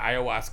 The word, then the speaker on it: ayahuasca: Is that how you say it? – Yeah, ayahuasca.